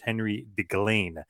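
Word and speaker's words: Henri Deglane: So 1931, the original quote-unquote Montreal screw job happens, and he screws Henry DeLange, or I think the correct pronunciation is Henry DeGlain.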